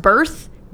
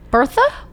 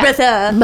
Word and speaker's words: Berthe: And her name is, I assume it is pronounced Berthe, B-E-R-T-H-E. – Bertha? – But there's no A, it's an E.